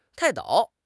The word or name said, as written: 泰斗